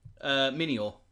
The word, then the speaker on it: Minior: Now, how would you say this? Minior? – Minior. – Minior. – As in mini meteor.